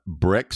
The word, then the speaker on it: Bryq: Brick sounds great, but it's spelled B-R-Y-Q.